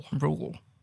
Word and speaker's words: Reuel: Full name, John Ronald Rule Tolkien. – Rule. – R-E-U-E-L. – Rule. – I was not 100% how to pronounce it. Rule.